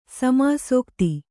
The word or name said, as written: ಸಮಾಸೋಕ್ತಿ